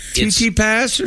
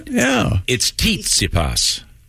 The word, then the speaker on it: Tsitsipas: Titsy pass or something? – It's like it's pronounced like Titsy fly. – That's right. – It's Tsitsipas.